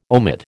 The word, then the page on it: omit: American English